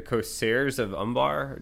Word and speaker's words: Corsairs of Umbar: I mean, I really liked the part where you mentioned, I want to make sure I say it right, the Corsairs of Umbar.